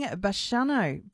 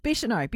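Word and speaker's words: Bicheno: Lisa Miller and the Backroads crew visiting Bishano. Bishano. Gosh, I don't know why I said that wrong. Bishano, beautiful spot.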